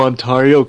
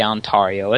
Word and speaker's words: Ontario: You don't say Ontario. It's Ontario.